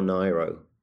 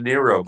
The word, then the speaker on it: Nyro: Is it pronounced Nero or Nyro? – I believe it's a Nero, because her name was Nigro.